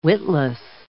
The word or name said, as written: witless